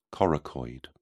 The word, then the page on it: coracoid: British English